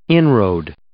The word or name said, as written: inroad